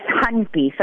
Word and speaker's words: Honke: It's Honke. So I want you to think H-U-N-K-E-Y. Nothing the way it's written.